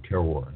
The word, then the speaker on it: terroir: T-E-R-R-O-I-R, terror, it's pronounced.